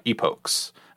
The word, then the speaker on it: epoch: Well, GPT E-P-O-C-H, told me... – Epochs, right? – GPT yeah. told me it was epoch, rhymed with... what did it say? – It could be. Very well, yeah. – Yeah. It broke was the second word it gave me. Rhymes with this, and it was broke.